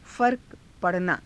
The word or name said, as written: farka_pa_ranaa